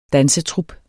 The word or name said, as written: dansetrup